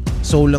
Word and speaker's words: Sowell: Sowell lang bigkas nila yan, pero Sowell ang spelling. So si Thomas Sowell ay isang Black academic.